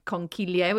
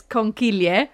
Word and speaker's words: conchiglie: And also, if they have some kind of hole, like a penne or a rigatoni, or like conchiglie, which are the shells. So yeah, it's not conchigli, everyone. By the way, you've been saying it wrong. It's conchiglie.